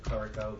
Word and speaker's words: Clerico: That's spelled M-C, capital D-O-N-O-U-G-H. I am the project landscape architect and also the project planner. – Robert Clarico, Van Cleek Engineering Associates, C-L-E-R-I-C-O.